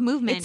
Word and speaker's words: MVMT: It's MVMT, though. Just to be clear, it's called Movement. – It's spelled MVMT. – Yeah, it's spelled MVMT. – But you say it Movement.